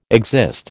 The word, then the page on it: exist: American English